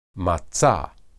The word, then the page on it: matsa: Hebrew